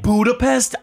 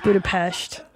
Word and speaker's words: Budapest: First of all, Austin, Texas, and Budapest are a little different vibe. – You didn't even say it right. It's Budapest.